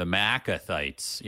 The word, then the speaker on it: Maacathites: Yeah, the Maacathites. Yeah, this is one of those weird things where the two A's are kind of like different syllables.